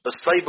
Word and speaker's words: الصيب: طب الصيب هو المطر، صيب، شوف، صي صي، يبقى تنطق النطق الصحيح الصيب.